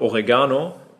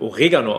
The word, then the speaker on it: Oregano: Oregano kennt man hier eigentlich nur aus, die meisten in Deutschland sagen auch noch Oregano, wird Oregano ausgesprochen, wird jeder Italiener bestätigen können.